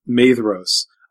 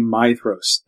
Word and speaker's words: Maedhros: He continually calls Feanor's eldest son Maedhros. It should be Maedhros, A-E in Elvish is pronounced, it's pronounced I, not A.